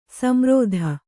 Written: ಸಂರೋಧ

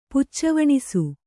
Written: ಪುಚ್ಚವಣಿಸು

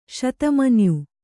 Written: ಶತಮನ್ಯು

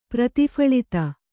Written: ಪ್ರತಿಫಳಿತ

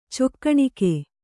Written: ಚಿಕ್ಕಣಿಕೆ

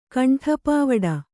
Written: ಕಂಠಪಾವಡ

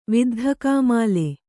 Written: ವಿದ್ಧಕಾಮಾಲೆ

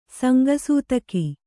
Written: ಸಂಗಸೂತಕಿ

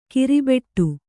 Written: ಕರ್ಬೆಟ್ಟು

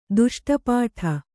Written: ದುಷ್ಟಪಾಠ

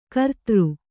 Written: ಗಿರಿಧರ

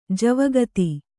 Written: ಜವಗಾತಿ

